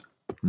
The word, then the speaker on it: Buffett: Platz 6, Warren, spricht man Warren Buffet? Oder Buffett? Buffett. Hm. Buffett. Warren Buffett.